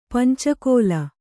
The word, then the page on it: ಪಂಚಕೋಲ: panca kōla